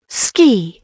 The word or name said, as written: ski